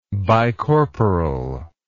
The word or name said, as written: bicorporal